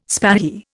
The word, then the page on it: spahi: Indian